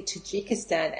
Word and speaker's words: Tajikistan: and obviously Tajikistan. And she says it with a J, Kirsty, so that's how you pronounce it, Tajikistan.